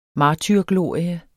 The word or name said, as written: martyrglorie